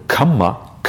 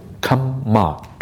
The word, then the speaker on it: kamma: It is not to be confused with the word kamma. Kamma is one word, kama is another word. There's a slight difference in pronunciation that is often missed by people who don't have an Indian background. And the kamma is a double M, and it's pronounced as if kamma, twice. Kama is a long A sound, and it's different.